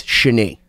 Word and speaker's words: Shani: So it's Shani, even though it's spelled Shani Kimmelman. It's Shani.